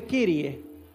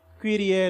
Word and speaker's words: Kyrie: La entonación uno dice Kirie, pero esa Y en griego se pronuncia más como una, una U francesa. U. Kyrie eleison.